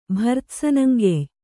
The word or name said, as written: ಭರ್ತ್ಸನಂಗೆಯ್